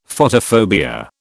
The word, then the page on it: Photophobia: British English